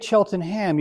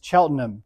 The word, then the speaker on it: Cheltenham: Now, it's spelled Cheltenham, but if you say Cheltenham, you're talking about a small township in Pennsylvania. So in England, it's Cheltenham,